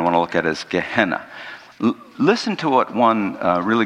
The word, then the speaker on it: Gehenna: It's actually pronounced Gehenna, but it's G-E-H-E-N-N-A.